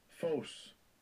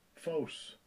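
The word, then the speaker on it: Phos (φῶς): Let me hit that pronunciation. – Strong's G 5457. Phos. Phos. – Phos.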